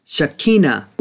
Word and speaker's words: Shekinah: Let's begin with an understanding of the Shekinah glory, or correctly pronounced Shekinah glory.